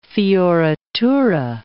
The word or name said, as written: fioritura